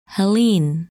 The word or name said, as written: Helene